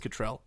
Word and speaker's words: Cattrall: Kim Cattrall, or Cattrall, it's spelled Cattrall, but everyone says Cattrall.